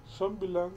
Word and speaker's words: Zombieland: Zombieland, sí.